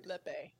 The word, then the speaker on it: Lepe: Lepe.